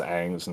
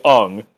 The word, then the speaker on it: Aang: It's just pronounced Aang, and you say Ung, and that's a different name.